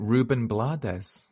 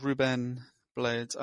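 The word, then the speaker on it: Rubén Blades: Hopkins pronounces it Ruben Blades. – Blades. – I've never heard. – Yeah. Well, the accents over the E in Ruben, sort of Ruben Blades.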